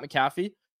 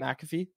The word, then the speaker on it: McAfee: Pat McAfee, or Pat McAfee, that's how you say it.